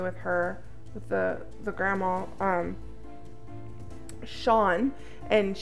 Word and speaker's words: Shaurne: And that's how you say it, but I love that it's spelled different than the Australians. Sean is spelled S-H-A-U-R-N-E. And I was like, Shireen or something the entire time.